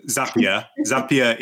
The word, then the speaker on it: Zapier: Zapier, I never remember how to pronounce that. – Zapier